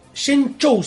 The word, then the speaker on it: Shenzhou: Shenzhou se pronuncia. – En realidad, depende. – ¿No? – Porque si lo decís en inglés es Shenzhou, si lo decís en chino posta es Shenzhou.